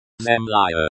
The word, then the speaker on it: Земля: Pronunciation. Земля. Note the stress is on the second syllable.